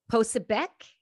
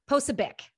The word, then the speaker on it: Posobiec: It's Jack Posobiec. Posobiec.